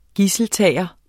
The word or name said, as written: gidseltager